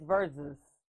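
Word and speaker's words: Verzuz: It's verses. – Verses? – Verses. But they have a Z in there.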